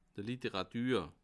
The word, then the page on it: d' Literàtür: Bas-Rhin; Haguenau